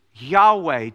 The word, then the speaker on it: Yahweh: But as I said, nowadays, most people, most biblical scholars, they think that probably the correct pronunciation is Yahweh to the name of the Lord.